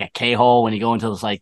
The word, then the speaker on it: K-Hole: Is it pronounced K-Hole? – Yeah. – Yeah. – All right. I don't know if they want like... – The K isn't silent.